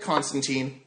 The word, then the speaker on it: Constantine: And, young, cool, hip Constantine, which is okay. And I'm saying teen because that's how I started. I know it's pronounced time. – Rhymes with wine.